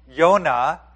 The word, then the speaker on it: Jonah: We are going to be looking this morning at Jonah, or Yonah, as it is pronounced in Hebrew.